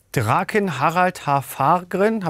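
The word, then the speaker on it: Draken Harald Hårfagre: Draken Harald Haarfagren, habe ich das richtig ausgesprochen? – Quasi, ja.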